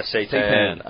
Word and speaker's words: seitan: All right, what's this fake meat stuff, this seitan or whatever it's called? Oh, yeah. Yeah, it was... – Is that how you say it? – Yeah, seitan.